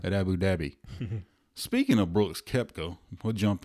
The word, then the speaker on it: Abu Dhabi: I think Brooks is playing at Abu Dhabi. – Yeah. – Abu Dhabi.